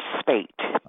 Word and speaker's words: Speight: And I want to make sure, Terry, am I pronouncing your last name correctly? Is it Speight or Spite or Speet or how does that go? – It's Terry Speight.